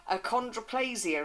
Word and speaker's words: achondroplasia: It's all about a little boy who has a condition called achondroplasia.